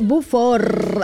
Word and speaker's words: Beaufort: El castillo de Beaufort, así lo decía, ¿no? Ahí está, lo pudimos escuchar bien cómo se pronuncia.